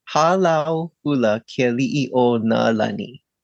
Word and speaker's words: Halau Hula Kali'i O Nalani: Halau Hula Kali'i O Nalani, is that correct? – Yes, it's pretty hard to pronounce even for me. It's Halau Hula Kali'i O Nalani.